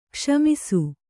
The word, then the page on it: ಗುಸುಮುಸಿ: gusumusi